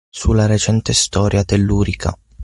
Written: recente

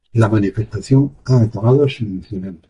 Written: acabado

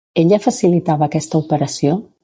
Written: aquesta

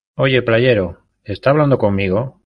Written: playero

